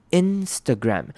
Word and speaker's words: Instagram: Instagram. Instagram 呢，还记得一开始的很久以前呢，有复习过这个字。那这个字也是台湾人很常念错的字。那 Instagram 其实就是台湾人常说的 IG，那它本名呢也是大家很常念错。 Instagram，首先注意到开头的 I 呢是短母音 I， Instagram 不是 in，是 in Instagram。